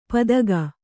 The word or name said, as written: ಪದಗ